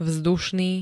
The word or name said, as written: vzdušný